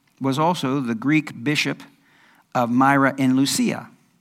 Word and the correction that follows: Lycia: Lycia. I said that wrong.